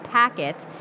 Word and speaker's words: PKT: That's what we're here for today, is talk about Packet, and it's spelled P-K-T.